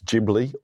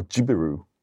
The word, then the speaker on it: Ghibli: I went to Japan recently, and I've just found out how to pronounce Studio Ghibli. It's Ghibli or Jibiru. Did you know that? – I did not know that.